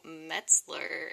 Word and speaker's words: Metzler: But it's April Metzler, and so it's got um got that whole German thing going on.